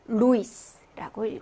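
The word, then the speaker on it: Luis: 뒤에 있는 거니까 이가 더 강하게, 이를 강하게 읽어주시는 거죠. 그래서 로이도라고 읽어주시면 되고, 루이스라고 읽어주시면 되겠습니다.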